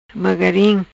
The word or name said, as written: Mogarim